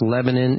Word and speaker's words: Lebanon: It's pronounced Lebanon. It's spelled Lebanon.